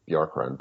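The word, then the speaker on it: Bjorkgren: That is the correct pronunciation of Bjorkren, by the way. I was told the Icelandic singer plus the bird is how you pronounce his name.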